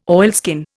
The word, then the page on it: oilskin: Indian